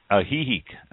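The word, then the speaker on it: Ajijic: For example, Solera, S-O-L-E-R-I, as opposed to S-O-L-E-R-A. So those are his suggestions. This is, again, from Bob Kraft, and he's in Hihek. No, I said that wrong. – Hihek? – Hihek. Okay, yes. Hihek. – Hihek. – Hihek. Yeah, that's the... – South of Guadalajara. – H-J-I-C, for all of those who know how to pronounce it.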